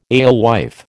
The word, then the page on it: alewife: American English